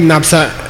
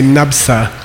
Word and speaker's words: Napsa: This is a case where the prefix letter of the second syllable is pronounced, okay? So we say Napsa.